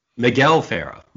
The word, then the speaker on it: Miguel Ferrer: This film stars Michael Ferrer. I probably said his name wrong. – Miguel Ferrer.